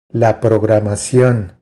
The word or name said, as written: La programacion